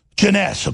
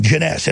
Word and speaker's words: Jeunesse: Jeunesse, okay, I say it wrong, Jeunesse.